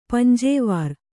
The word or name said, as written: ಪಂಜೇವಾರ್